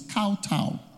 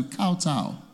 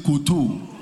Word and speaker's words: kowtow: It's kowtow. Check your dictionary. It's kowtow. Huh? K-O-W-T-O-W. It's kowtow. If you like, press your, you check your dictionary and press, there's a speaker, press it, to pronounce it to tell you kowtow. And you say koto. You should be saying kowtow.